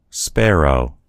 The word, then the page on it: sparrow: American English